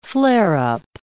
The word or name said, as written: flare-up